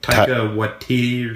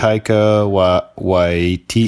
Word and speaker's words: Taika Waititi: Directed by Takia. – You don't know how to say his name. Don't pretend. It's like Taika Waititi or something. I don't know. – Taika Waititi. – It's a Maori name.